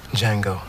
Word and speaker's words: Django: Django. Then you're exactly the one I'm looking for. – They caught my wife. They sold her, but I don't know who to. – Yes! – Woo! Gentlemen, you had my curiosity, but now you have my attention. – What's your name? – Django. – The D is silent.